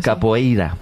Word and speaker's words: capoeira: Pues todos sabemos lo que es la copeira, ¿verdad? – Capoeira. – Gemala. Qué mal pronuncio últimamente.